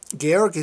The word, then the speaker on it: Georg: I was going to say George, Georg, but that would butcher it. – Yeah, George is, everybody says George internationally, but in his language is Georg in the Austrian German.